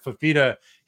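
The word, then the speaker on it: Fifita: Fafita, I think that's how you say his name. – Yeah. – Fafita.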